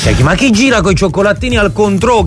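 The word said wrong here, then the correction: Cointreau: Si dice Quantrô. – Quantrô, manco so come si pronuncia.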